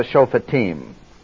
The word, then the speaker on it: Shophetim: Long O, real short E, and then I-M. Almost always when you're looking at a word I-M in the Old Testament, that's the Hebrew plural. And it is not pronounced M, it's pronounced Im. Like they went into the land, they were over at Shittim, S-H-I-T-T-I-M, the Cherubim, Urim, Thummim, and so forth. And these are the Shophetim, pronounced like a long E.